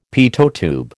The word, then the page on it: Pitot tube: American English